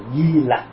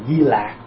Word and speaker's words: Di Lặc: Và đây là tên của vị Phật tương lai, và ngày nay chúng ta thường đọc là Di Lặc Phật. Có khi thì người ta đọc trại chút cho đọc là Di Lạc. Thật ra lạc lặc cũng được, nhưng mà đa phần mình đọc là lặc.